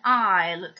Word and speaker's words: aisle: Quindi, come in aisle troviamo scritta questa S ma non viene pronunciata, in receipt troviamo una P che viene anch'essa omessa nella pronuncia.